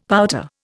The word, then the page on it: pouter: Indian